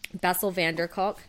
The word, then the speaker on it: Bessel van der Kolk: But he says in the book, this is Bessel van der Kolk, and I hope I said his name right. – But pretty close. It's good. Yes.